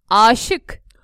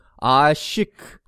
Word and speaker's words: aşık: I and then what's the other one? – Long A. A. – So for example. – Aşık. – Aşık. – Aşık in love.